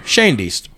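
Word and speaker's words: Schoendienst: Red Schoendeist? Schoendeist? Shandeist. Shandeist. Shandeist. Thank you. Shandeist.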